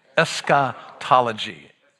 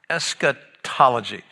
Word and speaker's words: eschatology: Eschatology. Eschatology. What eschatology is the study of the last things. That's all that cool, it's a cool Greek word, isn't it? Say it one more time with me. Eschatology.